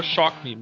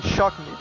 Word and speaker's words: Shock Me: Que tu falou Chalk Me, pode falar só Shock Me mesmo.